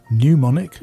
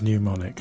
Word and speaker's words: mnemonic: hear mispronounced mnemonic. Yeah, well, it is mnemonic.